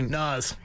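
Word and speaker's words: Nas: Naz. Yep. – Naz is his name? – Yeah, that's how you pronounce it. – So like Nazem Kadri.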